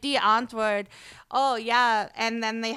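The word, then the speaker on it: Die Antwoord: And I love, I would say one of D'Antwoord, if anyone has ever heard of them. D'Antwoord, but it's spelled Die, D-I-E, Antwoord.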